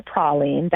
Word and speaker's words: praline: We offer praline. That word could also be pronounced praline, but it would be referring to hazelnut. So we offer the southern pecan, so it's praline, which we make praline candies the same way that we would have in New Orleans.